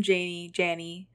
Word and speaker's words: Janney: Anything with Miss Perky, who is played by Allison Janney.